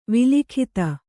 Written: ವಲ್ಗಿತ

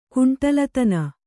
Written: ಕುಂಟಲತನ

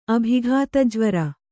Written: ಅಭಿಘಾತಜ್ವರ

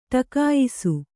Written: ಟಿಕಾಯಿಸು

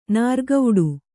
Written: ನೀರ್ಕುಡಿ

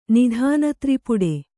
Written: ನಿಧಾನತ್ರಿಪುಡೆ

